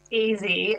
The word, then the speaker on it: Ezzy: One of the other stories that was quite delightful was how you highlighted, is it Ezzy? Is that how you pronounce it? Ezzy Moses's legendary spice shop in Bondi. – It's pronounced easy, as in it's short for Ezekiel.